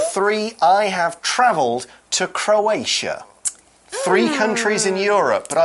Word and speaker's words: Croatia: I have been to Croatia.